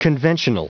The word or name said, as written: conventional